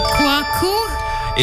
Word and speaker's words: quokka: They're quokkas, right? – I will accept that. – Quokka? – It is the quokka.